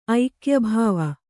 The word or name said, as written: ಐಕ್ಯಭಾವ